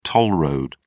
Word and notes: toll road: British English

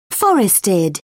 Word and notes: forested: British English

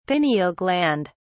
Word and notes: pineal gland: British English

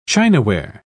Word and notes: chinaware: British English

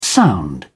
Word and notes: sound: British English

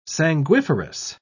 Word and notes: sanguiferous: British English

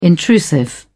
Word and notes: intrusive: British English

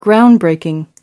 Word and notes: groundbreaking: American English

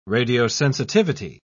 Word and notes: radiosensitivity: British English

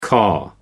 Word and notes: caw: American English

